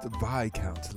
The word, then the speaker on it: Viscount: Oh, it's pronounced Viscount, like Viagra. – But how do you know Viagra? And why is the S silent?